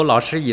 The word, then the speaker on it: 老师: 例如老师一词，这是第三声和第一声的组合，发音时我们首先要有意识地把第三声读在低音区，把第一声读在高音区，其次才是去关注第三声的发音。